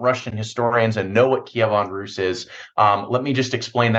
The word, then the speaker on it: Kievan Rus: I know that you created it as a tabletop simulation, the Mongol invasion of Kievan Rus. I don't know if I pronounced that correctly. – Yep.